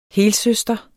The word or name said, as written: helsøster